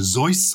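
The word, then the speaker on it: Seuss: I think it was Zeus, because it was Theodore Zeus Geisel. So it would have been Zeus, but I think in American English it became Zeus, because Zeus does sound a bit odd to the English tongue.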